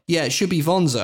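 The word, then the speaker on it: Wanzer: And it says, and I'm quoting the wiki here on the trivia section, the term Wonza should correctly be pronounced Vonza with a V.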